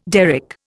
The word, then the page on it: derrick: Indian